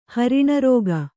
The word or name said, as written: ಹರಿಣರೋಗ